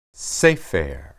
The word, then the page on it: sepher: hebrew